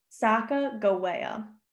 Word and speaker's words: Sacagawea: And to start us off, I'm switching over to the, like I said, the actual pronunciation, Sacagawea. And it's Sacagawea because in my research, there's not a soft G in the Hidasta language, which translates to bird woman.